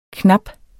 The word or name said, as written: knap